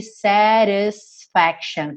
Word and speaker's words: satisfaction: A gente está muito acostumado com músicas com a pronúncia mais americana, onde a gente teria aqui satisfaction, com esse T reduzido para rararã.